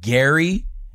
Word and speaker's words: Gerry: Even though Jerry's name is pronounced Gary, but we say it like Jerry, it's just whatever.